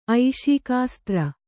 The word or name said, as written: ಐಷೀಕಾಸ್ತ್ರ